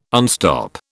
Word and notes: unstop: British English

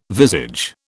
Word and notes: visage: British English

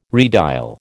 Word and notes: redial: American English

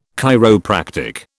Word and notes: chiropractic: British English

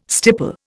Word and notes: stipple: Indian

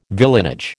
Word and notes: villeinage: American English

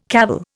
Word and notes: cavil: Indian

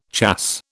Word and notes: chasse: British English

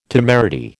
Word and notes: temerity: American English

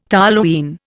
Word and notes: toluene: Indian

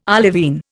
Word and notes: olivine: Indian

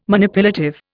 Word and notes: Manipulative: Indian

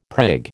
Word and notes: prig: American English

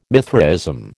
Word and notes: Mithraism: American English

